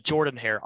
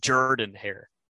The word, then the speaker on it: Jordan-Hare: You said Jordan-Hare. Auburn fans get very upset if you do not say their stadium correctly. It's Jordan-Hare. It's a very southern way of pronouncing Jordan, but it's Jordan-Hare.